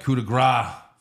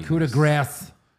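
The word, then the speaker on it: coup de grâce: It used to be the coup de grace. Now it's the coup de grace. – Grace. – Coup de grace. – Well, gras is how to say it.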